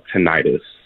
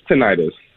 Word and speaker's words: tinnitus: So the ringing in the ear is officially called tinnitus. – Hey! – Then how come we're saying tinnitus or whatever it's called? – It's spelled weird. It's spelled like tinnitus, but it's tinnitus.